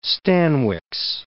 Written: Fort Stanwix National Monument

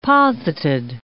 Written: posited

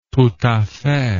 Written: tout a fait

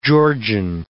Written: Georgian

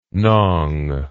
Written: nong

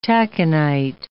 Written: taconite

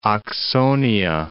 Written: Oxonia

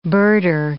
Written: birder

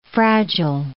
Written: fragile

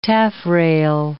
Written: taffrail

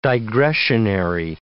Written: digressionary

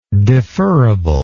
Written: deferable

